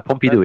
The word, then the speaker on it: Pompidou: I, I hope I pronounced that right, right? Donated. – You got it right. You got it right. Pompidou, right, yeah. Pompidou.